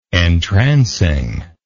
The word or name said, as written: entrancingly